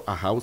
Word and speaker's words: AJAW: Le recuerdo, AHAU se escribe AJAW.